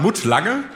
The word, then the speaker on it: Mutt Lange: Spricht man ihn, ja, glaube ich, aus, ne? – Also er hat deutsche Vorfahren. Wir würden sagen Mutt Lange. – Lange, aber ja.